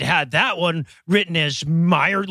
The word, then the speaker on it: Mayer: They're both pronounced Meyer.